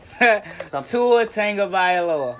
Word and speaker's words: Tagovailoa: I know you have now help me out with the pronunciation, because you know never good with names. – Uh-huh. – Tua Tagovailoa. – Tua Tagovailoa. – There you go.